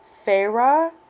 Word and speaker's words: Feyre: which is F-E-Y-R-E. I know that's not how it sounds like it should be spelled, but that's how it's spelled. It's spelled F-E-Y-R-E, but pronounced as Faerie.